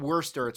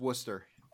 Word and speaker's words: Worcester: Worcester? I want to say Worcester. – Oh, Worcester. – It's not Worcester. It's Worcester.